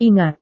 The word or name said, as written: ingat